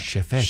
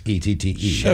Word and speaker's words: Chefette: Chefette. – Chefette. – Chef is spelled like chef and then ette. – Chefette.